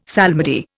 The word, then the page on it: psalmody: Indian